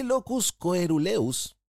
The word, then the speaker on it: locus coeruleus: Este locus coeruleus, ahí lo dije bien, dije bien, Ustedes se mueren cuando teníamos la radio, cómo me costaba decir norepinefrina.